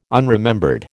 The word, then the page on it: unremembered: American English